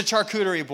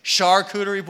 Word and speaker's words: charcuterie: Not the ch. It's a ch. Is it a ch? So it's like a ch, but like how you would pronounce Shane's name. It's like the only two things: charcuterie board and Shane with the ch.